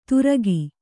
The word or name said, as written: ತುರಕಿ